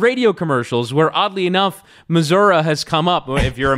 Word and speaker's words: Missouri: How do you pronounce the state that Kansas City, bigger Kansas City, and St. Louis are located in? – Missouri? – People in that state say Missouri. Missouri.